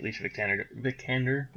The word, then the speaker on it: Alicia Vikander: Alicia Vikander, did I say that right? – You did.